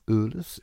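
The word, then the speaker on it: Uhls: Jim Ewells. – Ewells? – Ewells.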